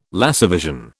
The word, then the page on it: laservision: British English